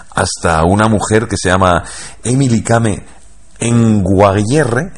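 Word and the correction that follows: Kngwarreye: y lo he dicho mal. – Emily Kame. – Con Guarreye. – Emily Kame con Guarreye, sí, sí.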